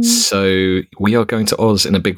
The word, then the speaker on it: Oz: Otzi. Is that I may be pronouncing that incorrectly. – Wizard of Oz. Wizard of Oz, I believe, is the common pronunciation.